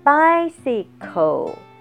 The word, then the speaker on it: bicycle: bicycle，bicycle，d b i c y c l e，c 这个字呢，它在英文里面它可以发。 s 的音，有的时候它发的是 k k 的音，所以在 bicycle 它一共发音有三段，高音在第一段，第一个 c 是发的是 s 的音，第二个 c 发的是 k 的音。